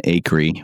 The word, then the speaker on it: Acree: Yeah, you can find me in places like Instagram and Twitter with the handle Evan Acree, which is my middle name that Andrew wouldn't let me talk about.